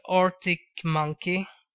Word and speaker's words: Arctic Monkeys: Så jag tycker då att Arctic Monkey, är det rätt uttal? – Ja, precis. Som bandet Arctic Monkeys, men utan plural.